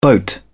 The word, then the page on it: boat: British English